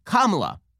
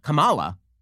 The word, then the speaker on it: Kamala: Because it's pronounced Kamala, not Kamala.